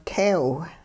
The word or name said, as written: teew